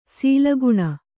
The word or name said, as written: ಸುಲಗ್ನ